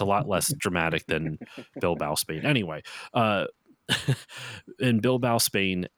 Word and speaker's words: Bilbao: Is that Bilbao? How do you pronounce that? – Bilbao. – That's what I figured. But I'm glad it's not Bilbo,